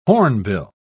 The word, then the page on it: hornbill: British English